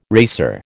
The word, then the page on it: racer: American English